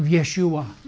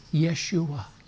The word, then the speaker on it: Yeshua: Jesus is not the right pronunciation of Yeshua. But that's all I knew was his name, Jesus. But the real name is Yeshua or Joshua